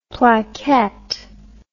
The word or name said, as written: plaquette